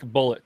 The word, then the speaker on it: Bulleit: It says it is pronounced just like Bullet.